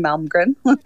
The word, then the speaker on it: Malmgren: It's at Dr. Pippa M, with just D-R-P-I-P-P-A-M, because nobody can pronounce my last name, Malmgren, except Swedes. – Right. Actually, the spelling of the last name is M-A-L-M-G-R-E-N. G-R-E-N, Malmgren.